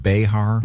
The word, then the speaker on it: Behar: Is it pronounced Behar? – Mm-hmm.